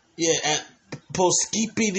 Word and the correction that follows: Poughkeepsie: New York, in the Mid-Hudson. – Oh, you mean Poughkeepsie is pronounced.